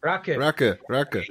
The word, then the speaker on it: Raki: Raka. You know what? I'll say it, and then in my head I'm like, no, you pronounced it wrong.